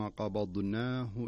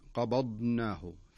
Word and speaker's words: قبضناه: الملاحظة التي قد يقع في الخطأ فيها في قوله قبضناه، لابد أن تنطق الضاد صحيحة فصيحة ثم تنطق النون المفتوحة، فلا تقول قبضناه فتصير تضغم النون مع الضاد.